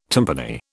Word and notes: timpani: British English

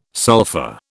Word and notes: sulfa: British English

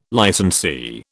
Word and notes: licensee: British English

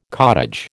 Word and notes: cottage: American English

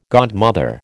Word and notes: godmother: American English

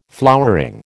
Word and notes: flowering: American English

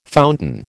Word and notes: fountain: American English

